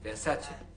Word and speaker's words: Versace: quoi Versace. On prononce Versace.